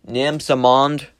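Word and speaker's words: Nansemond: And this is where the Elizabeth and, I believe it is pronounced Nam-Samond, rivers meet the James River just before, it would enter the Chesapeake Bay right next to the city of Norfolk.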